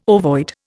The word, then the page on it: ovoid: Indian